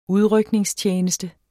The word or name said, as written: udrykningstjeneste